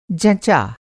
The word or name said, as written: जँचा